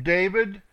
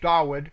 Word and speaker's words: Dawid: And these are they whom David, or Dawid, properly pronounced, Dawid, set over the service of song in the house of Yahweh after that the ark had rest.